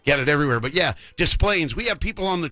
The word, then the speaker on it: Des Plaines: Just think like tattoo. Desplaines. But with an S.